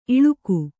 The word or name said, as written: ಇಳಿಕು